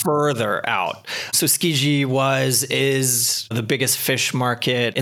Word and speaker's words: Tsukiji: Let's just leave it at Tsukiji. – Tsukiji.